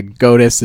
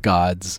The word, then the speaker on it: GOTIS: So anyways, yeah, GOTIS is the product. The correct way to say it, but I've heard like gods.